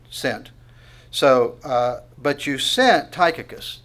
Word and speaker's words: Tychicus: And what's also interesting, if you look at it with me in verse 12, but and here's one, another one of these names that if you pronounce it according to the way it looks, it's going to be different. Tychicus is actually the way it's pronounced, but Tychicus would be fine.